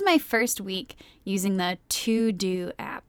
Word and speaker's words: Teuxdeux: So it is spelled T-E-U-X-D-E-U-X. So it's pronounced like it would be in French, which would be To-Do, but this does not mean To-Do in French, just so we're clear.